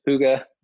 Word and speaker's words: Hygge: Hygge, pronounced Hygge, spelled Hygge.